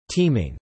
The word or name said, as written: teaming